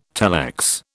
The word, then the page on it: telex: British English